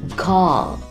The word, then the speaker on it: call: 要注意 call 这个单词里的字母 L，发音时要把舌头放到上齿背后顶住， call。很好， need, N-E-E-D, need, 表示需要的意思。